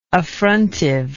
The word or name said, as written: affrontiveness